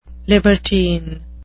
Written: libertine

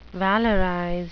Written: valorize

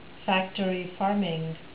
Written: factory farming

